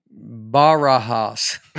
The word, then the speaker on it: Barajas: My last name is pronounced Barajas. It's the Spanish word for cards.